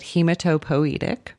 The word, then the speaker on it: hematopoietic: We say it hematopoietic.